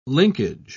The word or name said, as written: linkage